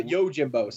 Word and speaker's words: Yojimbo: And Usagi Jimbo is going to be in 3 episodes. – Usagi Jimbo. Oh, the rabbit? – The rabbit. Yeah. The Yojimbo. Sorry, I always mispronounce that.